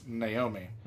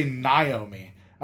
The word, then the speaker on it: Naomi: But on TV, her name is Naomi. – Okay. – Or, as too many people say, they say Naomi. And I'm like, that's an A, which is never pronounced like an I, but whatever.